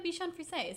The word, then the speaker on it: Bichon Frise: And then they have like a Bichon Frise.